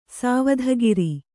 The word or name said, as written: ಸ್ವಧಾಕಾರ